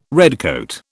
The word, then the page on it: redcoat: British English